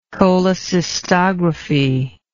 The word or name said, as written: cholecystography